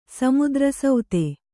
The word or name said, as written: ಸಮುದ್ರಸೌತೆ